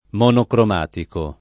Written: monocromatico

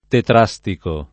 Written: tetrastico

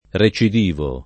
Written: recidivo

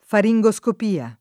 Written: faringoscopia

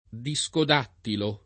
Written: discodattilo

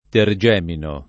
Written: tergemino

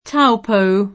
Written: Taupo

